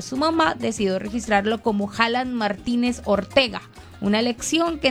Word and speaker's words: Haaland: En noruego esa doble A es una doble O. Es Haaland.